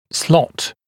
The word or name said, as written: slot